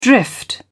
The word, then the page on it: drift off: British English